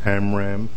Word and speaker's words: Amram: Mr. Chairman, members of the committee, my name is Fred Amram, A-M-R-A-M.